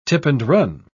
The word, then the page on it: tip-and-run: British English